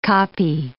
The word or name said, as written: kopje